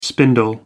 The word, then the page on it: spindle: American English